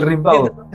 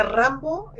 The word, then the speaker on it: Rimbaud: Oye, no sé cómo se pronuncia en francés Rambaud, pero creo que creo que así, como Rimbaud. – el Rimbaud. – Mientras, no, mientras no le diga Rambo, estamos bien.